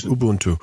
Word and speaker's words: Ubuntu: Ubuntu.